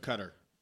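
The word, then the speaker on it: Koetter: Bucks, Dirk Coat. How do you say that last name? – Dirk Cutter.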